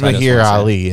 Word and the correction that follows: Mahershala Ali: isn't it? – Mahershala Ali. – Mahershala Ali, yeah.